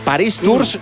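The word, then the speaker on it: Paris-Tours: París Tours se escribe. París Tour se pronuncia, Alexis.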